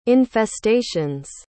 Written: infestations

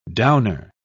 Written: downer

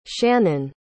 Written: shanon

